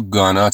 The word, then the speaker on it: going to: در انگلیسی spoken ما going to رو gonna تلفظ میکنیم. حتی اگر میخواین طبیعی تر و natural تر صحبت کنین میتونین to be رو هم بیاندازین، یعنی بگید We're gonna clean up this mess.